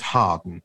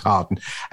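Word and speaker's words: Hawarden: And just to let listeners know, it's actually spelled Harwarden, village. – Sorry, yes. – But it's actually pronounced Harden. So, in fact, actually, I saw a newspaper, a letter in the Telegraph the other day from somebody, and he actually had misspelt it. And it is Harwarden, but it's pronounced Harden.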